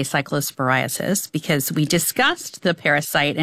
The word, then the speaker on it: cyclosporiasis: If you are worried, there is a CDC cyclosporiasis, I didn't pronounce that right, outbreak. – Cyclosporiasis. – Yes.